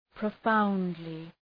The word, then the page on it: profoundly: prə’faʋndlı